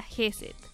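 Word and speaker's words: Hesed: O sea, ya, con H, pero se pronuncia geset.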